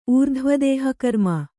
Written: ಊರ್ಧ್ವದೇಹಕರ್ಮ

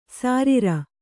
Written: ಸಾರೀರ

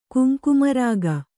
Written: ಕುಂಕುಮರಾಗ

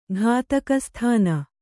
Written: ಘಾತಕಸ್ಥಾನ

